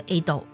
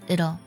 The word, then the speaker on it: it'll: 那同学们在去读这种发音的时候呢，在这个 T 的浊化上也不用特别的刻意，不要读作 it'll，不是 it'll，是 it'll，就那个的的音它会滑得很快，紧接着把那个的音读出来就行了。